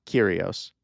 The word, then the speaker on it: Kyrgios: I mean, the Greek pronunciation of it would be Kyrgios. – Oh, here he right? goes. – So you would just say like Kyrgios is fine. Do not say the G.